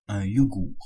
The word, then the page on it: yogourt: [œ̃ jo.guʁ]; Canada (Lac-Saint-Jean)